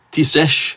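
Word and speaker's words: Taoiseach: Ja, sen finns ju kansler i Tyskland och Tisesh som det heter i Irland till exempel. Visste ni det? Tisesh, så kallas Irlands premiärminister.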